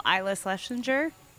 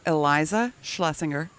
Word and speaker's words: Eliza Schlesinger: But I have watched her Netflix special, Isla Schlesinger. Close? – It's pretty bad. Pretty bad. – What is it? – Eliza Schlesinger.